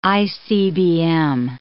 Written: ICBM

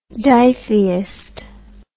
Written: ditheist

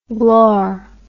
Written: glaur

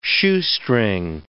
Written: shoestring

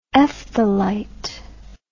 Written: ephthalite

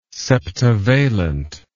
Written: septivalent

